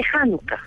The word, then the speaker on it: Hanukkah: Se pronuncia Hanukkah, Shanukkah, Chanukkah, ¿cómo se dice? Hanukkah. Hanukkah. Se dice Hanukkah.